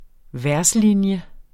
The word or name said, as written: verslinje